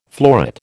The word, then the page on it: florid: American English